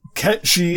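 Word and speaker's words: Cait Sith: when I assume he appears in part 2 or later in part 1, they pronounce his name Kaitsith or Ketchy, which is Ketchy is the actual pronunciation of those words in Irish, that name is.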